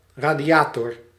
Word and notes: radiateur: [ʁa.dja.tœʁ]

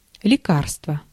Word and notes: remedy: American English; IPA: /ˈɹɛm.ə.di/

